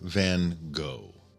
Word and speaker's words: Van Gogh: I'll say right away on the side that I'm going to pronounce his name Van Gogh. It's the American English pronunciation. I'm not adequately practiced in my Dutch, where Van Gogh would be perhaps a little, or Van Gogh would be a little more accurate.